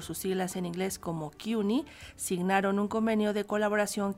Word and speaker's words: CUNY: signan el Colegio Nacional y el Instituto de Estudios Mexicanos de CUNY, así lo pronuncian ellos, CUNY, un convenio que enriquecerá la investigación sobre nuestro país y nuestros compatriotas en Estados Unidos.